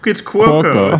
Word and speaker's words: Cuoco: No, it's Cuoco. It's Italian, not Japanese.